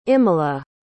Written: imala

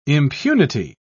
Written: impunity

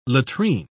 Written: latrine

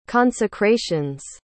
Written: consecrations

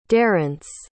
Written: darence

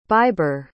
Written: baibre

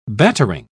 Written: battering

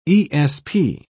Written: esp

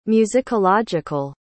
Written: musicological

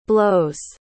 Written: blows

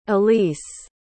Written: elyse

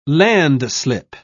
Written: landslip

